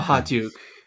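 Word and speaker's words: Okhotiuk: I can't even pronounce Nikita's last name. Otochuk? – Uh, Ahatuk.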